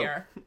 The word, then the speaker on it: Ciara: Is that how you pronounce it? – Ciara. Yeah. – Okay, cool. – You got it. – All right. – Here we go. I love Ciara.